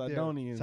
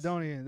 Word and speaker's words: Sidonians: And so Solomon worshiped Astaroth, I always mispronounce that, but the goddess of the Sidons. I should have practiced this beforehand. you read Sidonians. – There. Sidonians.